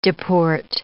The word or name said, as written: deport